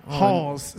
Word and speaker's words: Halls: Halls. It's called Halls. – Halls. – Spell that. – H-A-L-L-S. – Halls. Halls. Like the medicine candy.